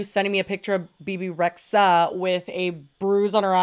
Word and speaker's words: Rexha: It's just Bebe Rexha. I think it's like a silent H.